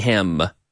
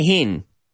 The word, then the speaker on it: him: Him. Not a hin. Him. We have the same pronunciation for him as in himno.